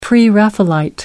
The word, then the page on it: Pre-Raphaelite: American English